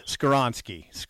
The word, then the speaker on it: Skoronski: I butcher his last name. So I was going to say Skoronski. Help me out. – You butchered it. Skoronski. Skoronski.